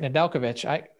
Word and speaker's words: Nedeljkovic: You said his name right, Nedeljkovic.